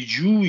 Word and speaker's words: jiu-jitsu: Então, quanto à grafia, né, esse, esse Gil, essa pronúncia Gil nem existe, existe em japonês, mas quer dizer outra coisa. Tanto Gil como Jitsu, né? Então a pronúncia, a pronúncia correta é entre Ju e Jiu, né?